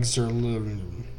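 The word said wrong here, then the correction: Zuerlein: You couldn't pronounce Zerline? Zerlin.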